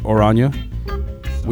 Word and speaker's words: Oranje: The Dutch pronounce it Oranje, we pronounce it Orange.